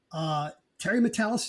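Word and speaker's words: Terry Matalas: But these people, Terry Metalis, is that the correct pronunciation of the showrunner? – Yes.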